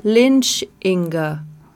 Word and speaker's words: Lynch Inga: And I said, "Lynch Inga." so how do you spell that? And I said, "Well, Lynch like Lynch and Inga like like Inga."